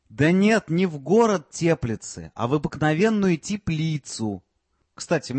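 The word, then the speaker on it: Теплице: Я для него рассаду помидоров привез из теплицы. – Теплицы. Ударение на первый слог. Это город такой в Чехии.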